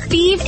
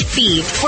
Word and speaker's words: Fives: Thebes is spelled F-I-V-E-S, like the number, but pronounced Thebes.